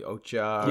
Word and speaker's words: Okja: And I haven't seen, I don't even know how to pronounce it correctly, Okja.